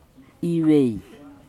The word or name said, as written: ivei